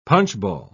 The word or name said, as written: punchball